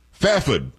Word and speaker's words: Fairford: Fafud is what I was told how I should pronounce it from K.